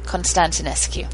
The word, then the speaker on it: Constantinescu: Constantinescu.